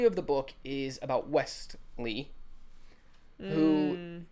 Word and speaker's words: Westley: There is a T in there. – No. – Yes. I looked it up. There's a T. – But everyone calls him Wesley. – It's fine. The T is silent.